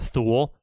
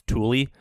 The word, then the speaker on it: Thule: I called it Thule. Apparently it's pronounced Thule. – I thought it was Thule too. Okay. – It looks like right, Thule. Fine. It rhymes with mule. Everyone's like, oh, hey, it's pronounced Thule. I was like, oh, come on. It's spelled like mule.